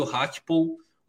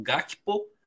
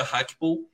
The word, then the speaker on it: Gakpo: E nesse ano aparece o Hakpo, ou você pode ler como Gakpo, mas é, é importante até aprendizado que o nome dele se pronuncia Hakpo e o Hagpon,